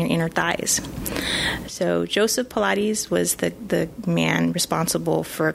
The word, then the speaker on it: Pilates: It's not pronounced Pilates. Pilates.